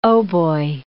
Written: hautbois

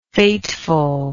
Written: fatefulness

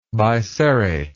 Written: biserrate

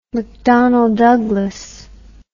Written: mcdonnell douglas

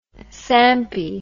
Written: sampy

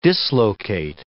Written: dislocate

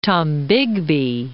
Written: Tombigbee

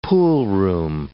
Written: poolroom

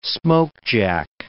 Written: smokejack

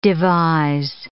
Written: devise

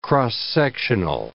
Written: cross-sectional

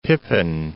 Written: Pepin, Lake

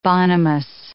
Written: bonhomous

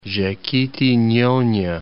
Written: Jequitinhonha

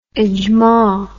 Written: ijma

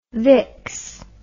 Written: Vicks

